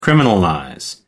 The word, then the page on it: criminalize: American English